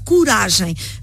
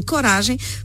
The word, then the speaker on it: coragem: Em português de Portugal, nós temos o som U, portanto, coragem. Já agora, coragem é uma palavra, coragem, palavra grave. O que é uma palavra grave? Tem o seu acento tónico na penúltima sílaba. E, portanto, como não é o O não está na sílaba tónica, em português europeu nós fechamos a vogal.